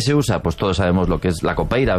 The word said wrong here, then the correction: capoeira: ¿verdad? Capoeira. Qué mal pronuncio últimamente.